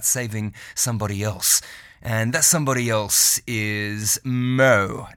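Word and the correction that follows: Mø: Now, I think I'm saying that right. Some people say Mo, some say Moo. Actually, let's just hear from her how to pronounce it. – You would say Moo. – Moo? – Moo. – Okay. – Oh, you almost got it. – I'll practice. It's the O with the slash through it, right? – Yeah.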